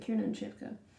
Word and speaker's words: Kiernan Shipka: So it's Kiernan Shipka. No, that's, yeah, that is how you pronounce her name. Kiernan Shipka.